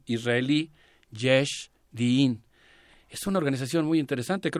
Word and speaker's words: Yesh Din: Se escribe Yeshdin como se, como se escucha.